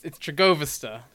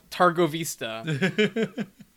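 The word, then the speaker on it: Târgoviște: Also, every time they mispronounce Trigovista, it just gets to me. It's Trigovista, not Targovista. Get it right.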